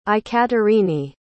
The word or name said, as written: aikaterini